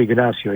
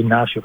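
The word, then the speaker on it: Ignacio: Λοιπόν, ο Ινάκη, Ιγνάσιο Πένια Σωτόρες, αν θυμάμαι καλά, το Ινάκη είναι το χαϊδευτικό του, Ιγνάσιο είναι, ή Ινάσιο όπως προφέρεται, είναι 27 ετών.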